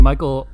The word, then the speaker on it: Mikal: Mikal is how you pronounce it.